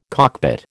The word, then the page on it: cockpit: American English